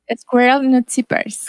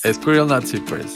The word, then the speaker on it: Squirrel Nut Zippers: Eh, Squirrel Nut Zippers. – Squirrel Nut Zippers.